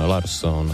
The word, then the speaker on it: Larsson: A rozhodl se, že se vrátí k původní české, v úvozovkách české výslovnosti, nebo té královské švédštině, řekněme, a bude se opět prý říkat Persson, Larsson a tak dále.